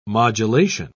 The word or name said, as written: modulation